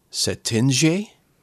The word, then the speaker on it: Cetinje: I'm going to call it Setinje. – Setinje. – It's spelled C-E-T-I-N-J-E.